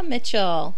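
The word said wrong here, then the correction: Michele: Michelle. – Sorry. Leah Michelle. I've been saying it wrong this whole time. I've always thought it was Mitchell.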